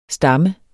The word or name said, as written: stammer